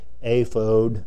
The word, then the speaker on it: ephod: Now I know that that's really a long O in ephod. It would be something pronounced something like aphod and so on, but that's hard for me to say, so I just call it ephod.